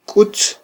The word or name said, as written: kutz